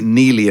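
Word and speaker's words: Neagley: And yes, I know they pronounce it Neely in the show, but that really annoys me because it has a G in it. The G is not silent, so I'm going to call it Neegley.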